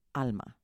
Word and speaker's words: ALMAH: And we say it as ALMAH, which means soul, spirit, in Spanish. And I thought it was a really important that one could pronounce it in Spanish and mean something to most people, and that it was easy to pronounce in English.